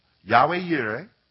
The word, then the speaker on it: Jehovah Jireh: Jehovah Jireh, Yahweh Yireh, depends on your pronunciation, means the Lord will see.